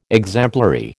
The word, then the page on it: exemplary: American English